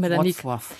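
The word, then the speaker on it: Wrocław: Ach, dann erzählt uns Florian Urbanski aus Wroclaw oder Breslau. Ich hoffe, ich habe das richtig ausgesprochen. – Wroclaw. – Ja, genau. Du kannst das besser als ich.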